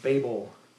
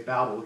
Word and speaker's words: Babel: That word Babel, sometimes we say Babel because that makes sense to us because they were babbling, but actually it's Babel because in the Hebrew that sounds like the word for confusion.